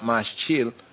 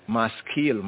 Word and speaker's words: maskil: that looks like maschil, but it's not maschil, maskil, maskil, maskil, maskil, right?